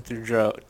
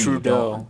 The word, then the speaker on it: Trudeau: So then he leaves the summit early and goes on a Twitter rampage against Canada and Justin Trudeau. I can't say his name. – It's not T. Just forget the TH. Just say it T. – Trudeau. – Don't think. Trudeau. That's how I pronounce it.